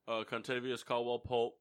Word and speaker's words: Kentavious Caldwell-Pope: Kentavious Caldwell-Pope.